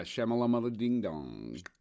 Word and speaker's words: Shyamalan: Come on, you have to say his name right. Like, that's fucking disrespect. – Does anybody really get his name right? Isn't that kind of M. Night Shyamalan? – Yeah, Shyamalan.